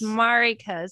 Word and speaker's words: Mariko: It's Mariko. The accent's on the first syllable, but you guys can just call me Marri.